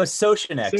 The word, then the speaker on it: Soucinek: And then, so Sosnick. So I always say it's Sosh and then a neck.